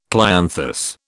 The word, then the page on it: clianthus: British English